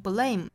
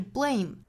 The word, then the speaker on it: blame: 尤其要强调一下 blame 这个词，昨天早读我们已经出现 blame 这个词了，那我格外强调了一下，在辅音连缀 b 和 l 之间不要突出元音了，不要读成 blame，应该是 blame， b b blame blame。